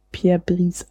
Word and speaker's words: Pierre Brice: Pierre Brice wird natürlich P Pierre Brice ausgesprochen.